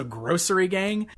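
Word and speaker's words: Grossery Gang: Grocery Gang, but it's spelled G-R-O-S-S, like gross. So Grocery Gang.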